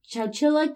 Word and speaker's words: Chowchilla: C-H-O-W. Chilla. Like chinchilla, but chow. So I'm like, is it chow or cow? I definitely listened to a video talking about this and promptly forgot how the guy pronounced it. – Chow it is.